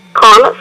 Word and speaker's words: Conner: J-O-H-N-I-T-A. – Okay. Last name? – Connor, C-O-N-N-E-R.